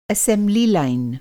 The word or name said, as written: असेम्ब्ली लाइन